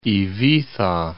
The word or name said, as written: Ibiza